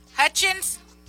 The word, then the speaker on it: Hutchins: Hutchins.